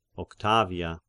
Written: Octavia

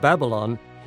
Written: Babylon

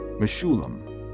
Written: Meshullam

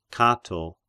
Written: Cato

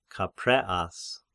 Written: Capreas

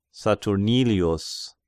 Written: Saturnilius

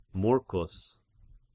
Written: Murcus

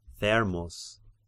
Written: Thermus

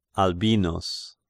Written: Albinus

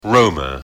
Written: Roma

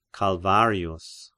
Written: Calvarius

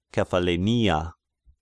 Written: Kephallenia

